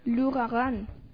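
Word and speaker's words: L'Ouragan: Un día como hoy, 29 de abril de 1901, en París, se estrena la ópera Leo Rougan. ¿Así se pronuncia? – No. – ¿Cómo es? – Lugarán.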